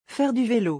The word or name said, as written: Faire du velo